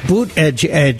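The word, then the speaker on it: Buttigieg: Boot Edge, that's how he told us to pronounce his name.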